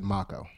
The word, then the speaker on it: Mako: In The Legend of Korra, they pronounce it Mako.